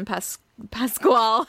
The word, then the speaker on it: Pasquale: I never know how to say his last name. – Pasquale. – Yeah. Okay, I'm going to hope that's right.